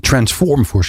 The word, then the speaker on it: Transform4C: Transform 4C, ik moet het in het Engels uitspreken.